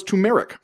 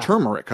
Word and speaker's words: turmeric: Turmeric, I believe is really? – How you say it. Yeah. – Turmeric, huh? – Because I was with you. I said turmeric as well. But I think it's turmeric.